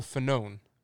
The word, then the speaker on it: Fanone: Yeah, so Andrew Clyde, Republican from Georgia, is walking through the House and Michael Fanone.